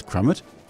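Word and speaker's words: Crumit: Thank you so much for joining me on Divertimento, and thank you for that pronunciation on Frank Crummett. I'm so glad it's Crummett.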